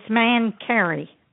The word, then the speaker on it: Mancari: Uh, it's Man Carey.